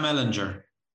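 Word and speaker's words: Ehlinger: How do you pronounce his name again? – Sam Ellinger.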